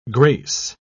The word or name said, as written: grace